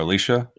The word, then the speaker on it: Alicia: I called her Alicia. – Yeah, because that's how it's pronounced.